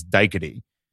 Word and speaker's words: Daiquiri: And then the proper pronunciation is Daiquiri, from what I've heard.